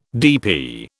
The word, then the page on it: DP: British English